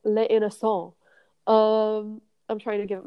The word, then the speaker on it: Les Innocents: Les Innocents. – Oh, like the innocent. Yes, yes. – Yeah, so it's like the innocence, but it's Les Innocents.